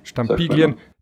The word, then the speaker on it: Stampiglien: Farbstampilien, spricht man das so aus? – Stampiglien,